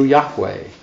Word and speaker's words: Yahweh: But the sort of central official name for God, as I'm sure you all know in the Old Testament, is normally pronounced in Hebrew, Yahweh.